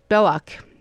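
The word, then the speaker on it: Bellocq: Also, somebody called saying that they remember seeing those photographs by Ernest Belloc, and that Belloc is spelled B-E-L-L, let me see, O-C-Q, Belloc is how it's French, in the Aperture magazine.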